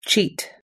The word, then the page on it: cheat: us-english